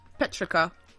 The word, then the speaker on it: petrichor: Petrica. – Petrica. – Yes. – Ah, Petrica. – Cute. – It is P-E-T-R-I-C-H-O-R, but it's pronounced Petrica.